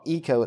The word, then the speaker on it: ICO: But this game is called Eco. It's spelled ICO, and it debuted on the PlayStation 2 in September of 2001.